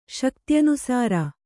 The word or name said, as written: ಶಕ್ತ್ಯನುಸಾರ